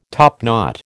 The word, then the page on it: topknot: American English